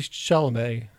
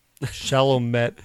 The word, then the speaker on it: Chalamet: Tim, O, and then T-H-E-E for Timothy. – Weird. – That's and Timothy to you. – Chalamet, probably. Or Chalamet? – Chalamet. – C-H-A-L-A-M-E-T. – You don't pronounce the last consonant in French.